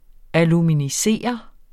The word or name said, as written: aluminisere